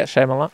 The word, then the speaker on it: Shyamalan: Shyamalan? – Yeah. Shyamalan.